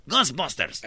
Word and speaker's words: Ghostbusters: Gasbusters. Ghostbusters, Ghostbusters.